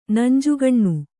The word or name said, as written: ನಂಜುಗಣ್ಣು